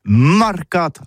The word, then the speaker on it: marcat: Eh bien, dis ça à tous, mais le mercat de l'Uni ou le mercat des rebelles, mais en gascogne toulousaine, c'est prononcé les marcat, avec un A, marcat. En gascogne toulousaine, bah, du côté, par exemple, de l'île des Baches, c'est l'île Jourdain, pour parler du marché, vous prononcez les marcat.